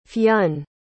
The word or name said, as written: Fionn